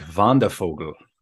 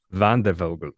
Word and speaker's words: Wandervogel: I think it would sound something like Wandervogel. – Wandervogel.